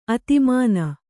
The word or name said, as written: ಆಧ್ಮಾನ